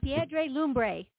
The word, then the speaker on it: Piedra Lumbre: It's a fantastic song, and I think we pronounce it Party on Piedra Lume. – All right. Lumbre. Sounds good to me. – Party on Piedra Lumbre.